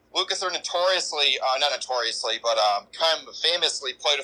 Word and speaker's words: Lukather: Yeah, I was gonna say that I think Colin Hay and the guy from, is it Lukather, is that how you say his name? – Yes.